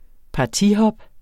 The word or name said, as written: partihop